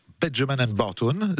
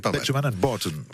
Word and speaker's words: Benjamin & Barton: Je le prononce bien ou pas ? – Perfect. – Ah, c'est ça, il est bien. Parfait, c'était parfait. pas mal. – Benjamin & Barton, ouais. Ouais, plus comme ça, avec l'accent british.